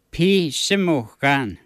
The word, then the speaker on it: pisimukan: In Michif, how do you say clock? – Bishimukan. – Bisimukan. – Pisimukan.